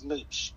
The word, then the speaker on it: Mooch: My alias, my name in the podcast for my friends, my name is Mooch in the podcast. – Wait, how do you spell that? – M-O-O-C-H. – Oh, Mooch, M-O-C-H. – Yeah, like a moocher, because I do mooch on people's foods.